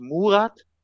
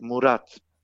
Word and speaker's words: Murat: Es heißt zum Beispiel auch, es, es heißt auch nicht Murat, es heißt Murat.